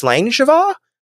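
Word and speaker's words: Sláinte mhath: In the subject line, and I'm hoping I am reading this correctly because I researched this just to make sure, but I believe it says Slangeva, and that is how you're supposed to pronounce it, even though it wouldn't be read that way in English.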